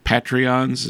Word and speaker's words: Patreon: And then also, we would invite your participation to support the podcast by being one of our Patreons. Is that the correct pronunciation? – Yes, a patron on Patreon.